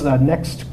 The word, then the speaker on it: NXT: I think it's Next Crypt, NXT. It's not, they pronounce it Next, but there's no E.